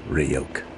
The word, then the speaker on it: Ryuk: It's pronounced Ryuk.